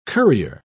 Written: currier

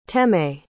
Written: teme

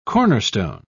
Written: cornerstone

